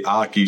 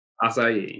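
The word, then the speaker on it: açaí: I say Aki, she says Acai. – Acai, yeah. – Acai, all right.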